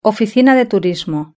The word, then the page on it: oficina de turismo: Spain Spanish